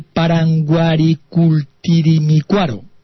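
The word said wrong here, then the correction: Parangaricutirimícuaro: Pero Paranguaricutirimícuaro. Vaya. – Exacto, porque me faltaba ahí la tilde.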